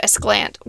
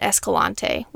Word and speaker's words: Escalante: But from there we went to Esclant, which was, we learned, that's how the locals say it now. This is kind of like our, the way we learned to say saguaro. Remember there were lots of times on the podcast we didn't say it correctly. Last year we were calling it Escalante, which is what it looks like.